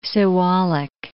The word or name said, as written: Siwalik Range